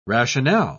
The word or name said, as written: rationale